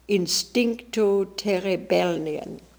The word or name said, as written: Instincto terebelnian